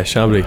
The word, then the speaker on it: Chambly: Yeah, Chambly.